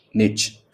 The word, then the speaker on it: niche: And in this episode, I'm going to teach you how to pronounce the word N-I-C-H-E. So there are two pronunciations of the word. In American English and American accent, the word is pronounced as niche. Okay, so it's like more like ch. It's like niche, N-I-C-H. Although the spelling is N-I-C-H-E, I know that. And in the British accent, it is pronounced as niche, like more like N-E-E-S-H, niche.